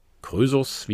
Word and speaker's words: Krösus: Ist es auch eine Zeit lang her, aber nicht ganz so lange her, das ähm, spricht man dem König Kreusos zu oder Krösus, wie es eingedeutscht heißt,